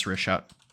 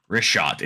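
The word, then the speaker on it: Richat: Yeah, it's Rishat. – Rishat. It's R-I-C-H-A-T.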